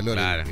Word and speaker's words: Loren: Loren se escribe como suena, Loren.